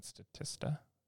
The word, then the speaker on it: Statista: So I'm looking at that Statista, is that how you say it? Statista? Yeah.